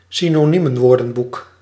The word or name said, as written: synoniemenwoordenboek